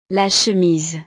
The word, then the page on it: la chemise: frances